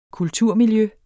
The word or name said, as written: kulturmiljø